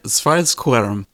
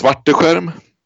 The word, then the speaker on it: Svarteskerm: Yeah, so it's it's Svarteskerm. So you almost nailed it, like 99%. that means I get a passing grade.